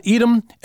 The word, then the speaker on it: Edom: Now, with Edom, we supply the vowel sounds of E and O. With Adam, we supply the vowel sound of A. So you got Edom and Adam, Edom and all people, Edom and all humanity.